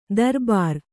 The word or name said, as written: ದರ್ಬಾರಿ